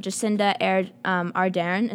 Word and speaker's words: Jacinda Ardern: So we spoke earlier in the show in our little like introductory news segment that Jacinda Ardern, is that how you say it? – Yeah.